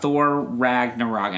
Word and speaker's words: Ragnarok: Next, we're going to talk about Thor Ragnarok.